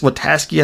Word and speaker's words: Witaske: And we, we, have that great scene with Chris Witaske, I think is how you pronounce his name. Yes, that's his name.